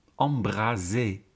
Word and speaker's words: embraser: Embraser, met een enkele S, is niet te verwarren met embrasser, met een dubbele S. He, je weet, één S spreek je uit als een Z en twee S'en als een S.